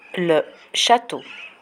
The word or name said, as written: le chateau